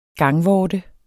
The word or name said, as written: gangvorte